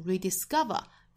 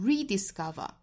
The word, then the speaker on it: rediscover: 那像这个单词本身读是第三音节重读 rediscover，哒哒哒哒。但是呢，这个词可以读成 rediscover，在第一音节重读。